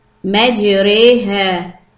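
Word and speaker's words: مجراها: This will be pronounced as re, not raw or re. – Majreha wa mursaha.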